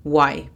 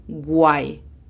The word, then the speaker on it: why: Pasamos a la segunda palabra. Why. Igual que where, a menudo se pronuncia why, cuando la pronunciación correcta es why. Imagínate que está la U y después viene la A. Why. Why.